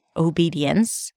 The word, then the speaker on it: obedience: The first one is O, as in go at the beginning, then B, high E, and then another high E in the next syllable, D, so O-B-D, and then a schwa sound, uns, obedience. You have a small y sound there in between. Dience. Obedience.